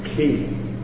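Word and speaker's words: chi: So now we come to this one is pronounced ki. You got to put some in the back of your tongue there. You don't have to, but you can go the easy route and say k, as in Christ.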